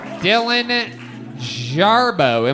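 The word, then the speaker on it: Dillon Jarboe: Dylan Jarbo.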